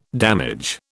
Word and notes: damage: British English

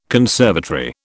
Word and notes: conservatory: British English

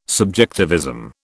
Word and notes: subjectivism: British English

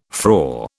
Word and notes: frore: British English

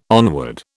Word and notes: onward: British English

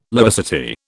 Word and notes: laicity: British English

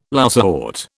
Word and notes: lousewort: British English

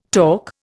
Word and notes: torc: Indian

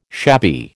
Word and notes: shabby: American English

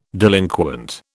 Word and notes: delinquent: British English

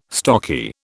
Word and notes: stocky: British English

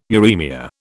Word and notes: uremia: British English

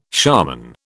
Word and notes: shaman: British English

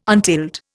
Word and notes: untilled: Indian

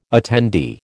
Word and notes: attendee: American English

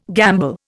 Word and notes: gamble: Indian

